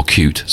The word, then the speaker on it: Qt: So it's the very latest kind of Qt technology, or Qt, as we've been told to pronounce it.